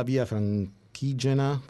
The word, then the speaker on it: Via Francigena: Allora, si parla di 30 ore di corsa alla via Franchigena.